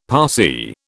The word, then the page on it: Parsee: British English